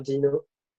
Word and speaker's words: Deno: Alors déjà, il y a débat sur la prononciation. On va dire que coupe la bullshit. – Non, mais les anglophones commencent de plus en plus à dire Dino, en particulier l'équipe Core, et d'où le branding autour du dinosaure.